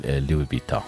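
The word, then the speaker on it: Louis Vuitton: أيضا عندي أيضا شيخة، شكرا على المسج، تقول ماركت لوي فيتان. هو فعلا مثل ما ذكرت أحيانا تنطق، يعني البعض يعني ينطق الاس، البعض يقول لوي فيتان.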